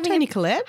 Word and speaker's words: Toni Collette: Did I just get her name wrong? Is she Toni Collette? – Yeah, Toni Collette.